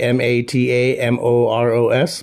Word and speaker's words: Matamoros: It was in uh, Mata Matamoros. I don't know if I'm saying that right. M A T A M O R O S. Matamoros.